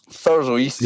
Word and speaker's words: Thurso: Thurso East